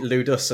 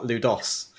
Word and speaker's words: Ludus: I watched one of the videos before I did it to make sure I pronounced it Ludus and not Ludos.